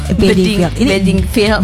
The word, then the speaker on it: Bedingfield: Bedingfield.